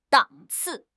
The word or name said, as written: 档次